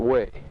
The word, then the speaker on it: Hue: Now Way is spelled H-U-E, and it is pronounced Way. Onkay is pronounced Onkay, not Onkay. So, Quantry, I don't know how it's spelled, Q-U-A-N.